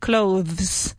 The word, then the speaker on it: clothes: Now, funny thing about clothes, me he matado media vida intentando decir clothes. And then somebody told me, as native speakers, we don't really pronounce the TH. It's just clothes.